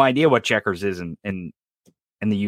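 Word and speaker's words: Chequers: And I think you would pronounce it checkers, like the game checkers, right? Yeah, it's C-H-E-Q-U-E-R-S.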